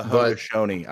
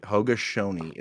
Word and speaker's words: Haudenosaunee: The Haudenosaunee. I got it right. Haudenosaunee is how you pronounce that.